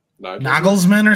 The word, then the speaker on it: Nagelsmann: I've been saying the former Bayern manager's name wrong. It's Julian Nagelsmann or something? – Yeah. – I would say more nay than nah, and I was definitely saying Julian. So. – Oh, I was definitely saying Julian, too. – We're not good with Jays. – Joe Felix's name wrong, too. He had said it was like Felix. – That is true. – He's like, even people in Portugal are saying it wrong.